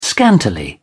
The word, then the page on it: scantily: British English